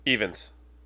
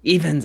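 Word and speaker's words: Rhys Ifans: I still think I pronounce Reese Ifans. – Evans. – Evans. I was close.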